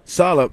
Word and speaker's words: Salah: Because, you know, there's a lot of fans back there that like, you know, Salah. Am I saying his name right? – Salah.